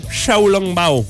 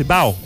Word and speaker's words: Xiaolongbao: C'est Xiaolongbao, qu'il faut prononcer. Bao, bao, Xiaolongbao. Donc, c'est originaire de Shanghai, le Xiaolongbao.